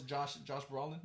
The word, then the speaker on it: Brolin: But, like, had you just painted, like, what was Josh Brolin?